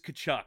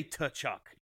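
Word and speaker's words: Tkachuk: I know their name is Kachuk. It's so hard for me not to silence the K and pronounce the T. Like, I want to say Tuchuk every Tuchuk.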